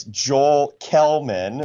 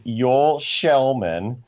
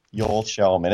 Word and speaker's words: Joel Kellman: If you read his name, it's Joel Kellman. – Thank you. – The correct Swedish pronunciation is Joel Shellman. So yeah, Joel Shellman.